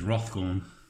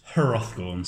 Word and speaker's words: Hrothgorn: I think it's Rothgorn. – I think too. I think there's the H is silent. – The silent H. – Yeah. Because Hrothgorn seems a bit.